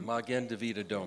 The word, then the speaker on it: Magen David Adom: Well, like ambulances, you know, how do you pronounce it? Magnum Adam? – Magen David Adom.